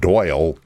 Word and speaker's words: Doyle: You're going to find that at Doyle King on Facebook. My first name is spelled D-O-Y-L-E.